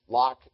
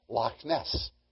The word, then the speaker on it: Loch: Now, we usually just pronounce Loch as L-O-C-K. That's not the word. And no good Scotsman would let us get away with saying Loch Ness. He'd want us to say Loch Ness.